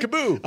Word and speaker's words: KAABOO: All right. Kaboo. I said it right, didn't I? – Kaboo.